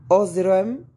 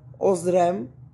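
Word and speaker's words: uxorem: Dixit que ad Laban da mehai ozrem. Yeah, ozrem. Because that X be making a Z sound. Like Xerxes, but it's spelled with an X.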